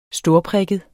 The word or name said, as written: storprikket